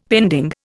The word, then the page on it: pending: Indian